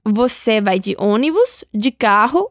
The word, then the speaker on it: carro: Entonces, el sonido de la doble R en portugués es como la J en español, un sonido de la garganta, carro.